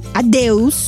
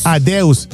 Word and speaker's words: adeus: Adeus. – Adeus.